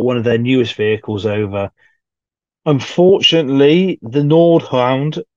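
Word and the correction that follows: Nashorn: got actually, Nashord, sorry, I should say correctly, to pronounce it correctly.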